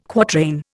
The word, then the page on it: quatrain: Indian